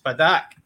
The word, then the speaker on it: Padak: Padak. – Padak. How do you spell that? – Padak! P-A-D-A-K.